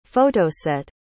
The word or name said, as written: photoset